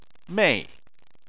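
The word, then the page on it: mei: tone 4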